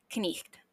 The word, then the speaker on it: cniht: It is spelled C-N-I-H-T and pronounced knicht.